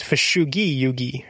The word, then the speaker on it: Fushigi Yugi: Because, like, it's not a soft G, right? It's Fushigi Yugi, right? Like, it's Japanese.